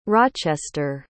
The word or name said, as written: rochester